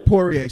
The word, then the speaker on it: Poirier: Is it Poirier? – Poirier. – Sorry about that.